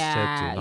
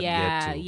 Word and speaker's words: chetu: It's like that is technically our food because it's like okay, so it's Chetu, not Yetu.